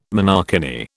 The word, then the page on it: monokini: British English